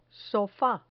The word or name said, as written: sofa